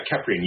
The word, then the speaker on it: Keprian: So you belong, I hope I'm pronouncing this correctly, you belong to the Keprian vampire tradition? – Yeah, Keprian, you got it correct. And a lot of people pronounce it wrong, and Egyptologists have even said that I might be pronouncing it wrong. So it's all good. We'll go with Keprian, and that's going to be our common pronunciation tonight.